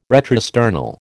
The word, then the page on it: retrosternal: American English